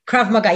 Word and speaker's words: Krav Maga: One of the few female instructors in the elite commando division, specializing in, help me if I say this wrong, Krav Maga? – Krav Maga, yeah, it's a Hebrew word.